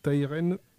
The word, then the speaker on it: Tyren: Tyren. – Ça s'écrit T-Y-R-E-N.